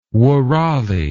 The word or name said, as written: woorali